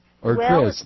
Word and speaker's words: Crist: And one's name is Charlie, C-R-I-S-T. – Charlie Christ? – Christ? It's pronounced Christ, but on paper.